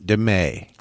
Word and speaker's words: DeMate: The T would be silent in that, devil. The T is silent. DeMay.